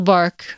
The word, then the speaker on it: barque: when this little bark of your body will depart. Bark is spelled B-A-R-Q-U-E, the French word for boat.